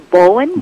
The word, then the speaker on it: Bowen: Did I pronounce that right, Bowen? – It's supposed to be Bowen, B-O-W-E-N.